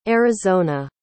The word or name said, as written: arizona